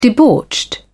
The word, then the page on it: debauched: British English